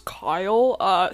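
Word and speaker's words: Kile: So, my name's Kyle, spelled with an I, just FYI. Everyone thinks I spell it wrong, but yeah, that's how it is on my birth certificate. So, you're the wrong one. So it's K-I-L-E. – Kyle, like, bile.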